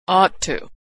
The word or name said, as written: ought to